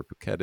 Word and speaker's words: Phuket: If anybody, you know, knows where Phuket is, but oh, that's how you pronounce it. – Yeah. Yeah. Yes, it is.